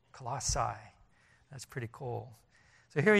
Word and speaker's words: Colossae: Well, this church, it's pronounced different ways. I'm not very good with pronouncing words anyways. It's Colossae. Everybody calls it Colossae. It's actually Colossae.